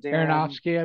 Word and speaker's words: Aronofsky: I love how do I say his last name? – Aronofsky, I believe. – Yes.